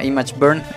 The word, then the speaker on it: ImgBurn: Y bueno, pues yo les voy a recomendar un programa muy sencillo, pero muy poderoso, que se llama ImageBurn. De hecho se escribe ImgBurn.